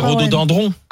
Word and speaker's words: rhododendron: Attends, deux secondes, parce qu'on dit rhododendron. – Dindron. – C'est piégeant. – C'est dendron. Alors c'est rhododendron. – Ah bon, oui. – Est-ce qu'on y a... Ça s'écrit dendron.